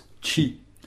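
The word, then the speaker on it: ĉi: Et ce petit mot qui va distinguer entre « ici » et « là » s'écrit « c » chapeau « i » et on le prononce « chi ».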